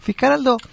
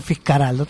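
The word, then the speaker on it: Fitzcarraldo: Y los peruanos pronunciaban Fiscaraldo. Entonces él le puso así la película.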